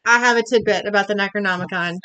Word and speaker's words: Necronomicon: The book, the Necronomicon. Did I say that right? – You did.